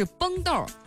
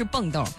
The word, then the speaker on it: 崩豆: 谢谢大家纠正我的读音哈，啊，应该是崩豆，不是蹦豆。